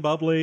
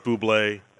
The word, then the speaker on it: Bublé: And then Hamm and Bubbly. – Hamm and Bubbly, even though he says, oh, it's pronounced Bublé.